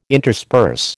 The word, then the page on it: intersperse: American English